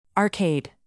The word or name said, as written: arcade